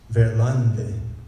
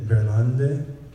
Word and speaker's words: Verlande: To help us understand this a little bit, I'm going to tell you the story of Jacques Verlande. It's spelled, Jacques is pretty easy, J-A-C-Q-U-E-S, and his last name is Verlande, or Verland, is V-E-R-L-A-N-D-E.